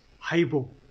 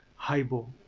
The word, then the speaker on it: haibo: that you come across so the word is haibo try saying that haibo that's how it's pronounced the word is haibo so I so the,